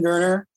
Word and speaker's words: Goerner: Yeah, so look up my name, Ben Goerner, G-O-E-R-N-E-R. – G-O-E-R-N-E-R.